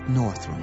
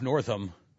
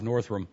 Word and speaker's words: Northam: Also interesting, by the way, did you pick up, Cameron, the announcer mispronounced Ralph Northam's name? – No, I missed that. – And, the last time she said Ralph Northam, she said Ralph Northam.